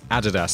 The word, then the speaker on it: Adidas: And I think I just should slot in a fun bonus fact before we get started with the episode that the pronunciation of Nike in the UK, where I'm from, is Nike, and Adidas, we pronounce Adidas.